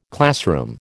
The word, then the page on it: classroom: American English